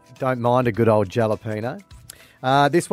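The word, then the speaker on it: jalapeño: Pronouncing the J in jalapeno makes you a subtle bogan.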